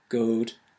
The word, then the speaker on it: good: So if we go back to our example, good and blood rhyme because both were pronounced goad and blowed.